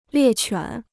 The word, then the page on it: 猎犬: liè quǎn